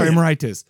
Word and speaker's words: Emeritus: The succession of the pop emeritus. How do you say that? – Emeritus is fine.